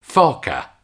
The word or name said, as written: foca